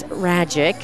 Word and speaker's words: Ragic: The loss, it's unbelievable because Max Ragic, which rhymes with magic, was masterful on the hill.